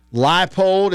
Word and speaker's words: Leipold: Leipold is how you pronounce it. I want to thank y'all for sending in the pronunciation.